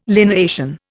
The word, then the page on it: lineation: Indian